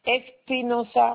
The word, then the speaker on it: Espinosa: Mon nom c'est Espinosa Molina, en deux mots séparés.